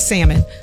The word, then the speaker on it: salmon: the L is silent. It's salmon.